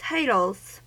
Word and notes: titles: American English

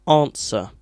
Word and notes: answer: British English

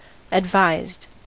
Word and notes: advised: American English

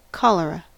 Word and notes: cholera: American English

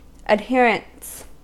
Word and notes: adherents: American English

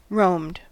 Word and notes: roamed: American English

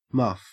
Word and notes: muff: Australian English

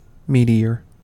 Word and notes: meteor: American English

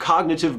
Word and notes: cognitive: American English

